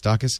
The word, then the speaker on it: Dachis: D-A-C-H-I-S, Dacius.